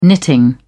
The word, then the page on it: knitting: British English